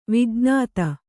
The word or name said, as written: ವಿಜ್ಞತೆ